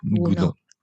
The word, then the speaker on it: Gounod: La de Ogunó es más romántica. Del siglo XIX. – Ya. – Claro, la de Gounod, que se escribe así. – Gounod. – Y se dice, se expresa Gounod.